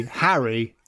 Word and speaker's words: Harry: In English or correct accent, the difference would be Harry and hairy. It's a very different vowel sound.